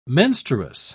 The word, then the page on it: menstruous: British English